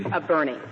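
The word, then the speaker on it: Boerne: We'll hear argument now, number 94-2074, the city of Burney versus Flores. Is that the correct pronunciation of the city? – Burney. – Burney, thank you.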